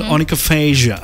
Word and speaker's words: onychophagia: So it's called onychophagia. – Onychophagia. – It's spelled with O-N-Y.